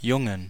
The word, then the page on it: Jungen: [ˈjʊŋən]